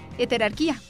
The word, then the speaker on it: heterarquía: Por eso me fui a buscar cómo se pronuncia y efectivamente es con tilde en la I, es decir, heterarquía, como tú lo estás diciendo, Diego.